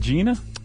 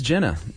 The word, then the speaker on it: Gina: Is it Gina? – It is Jenna. Okay. It is spelled Gina. And Jerry told me how to pronounce it.